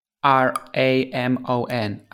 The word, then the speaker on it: Ramon: So my name it's spelled like A L E I X R A M O N Aleix Ramon, and you can find me on Twitter as Aleix underscore Ramon.